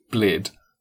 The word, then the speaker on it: Bled: And the famous lake, spelled B-L-E-D, is pronounced Blid.